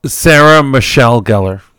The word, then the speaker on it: Sarah Michelle Gellar: Sarah Michelle Gellar.